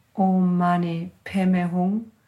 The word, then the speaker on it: Om Mani Padme Hum: We will chant the Tibetan Buddhist version, which has the pronunciation Om Mani Padme Hum, together with the Gyuto monks.